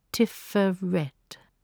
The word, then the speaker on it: Tiphereth: It is spelt K-E-T-H-E-R, but it is pronounced Keter. Inside the second circle, write Tiferet, spelt T-I-P-H-E-R-E-T-H, but it is pronounced Tiferet. Inside the third circle, write Yesod, spelt Y-E-S-O-D, and pronounced almost as it is spelt, Yesod.